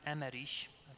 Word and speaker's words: Emmerich: If you have watched The Passion by Mel Gibson, it's based on the visions which were approved by the church of Blessed Catherine Emmerich, is how it's pronounced in German, a Catholic nun.